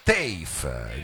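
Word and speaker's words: TEIF: acronimo che si, si pronuncia TAFE, dico oh, ho detto bene, allora eh, che sarebbe l'acronimo di The Earth is Flat.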